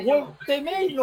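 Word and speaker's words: Woltemade: 41, Nick Woltemaid o, o Woltemaid no sé cómo se dice título es W-O-L-T-E-M-A-D-E. E. – Tal cual, tal cual lo dijiste.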